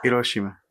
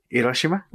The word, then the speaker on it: Hiroshima: I pronounced them correctly, by the way. Not Hiroshima. Hiroshima. – Oh, okay. You know what I'm saying? Do that again. – Hiroshima.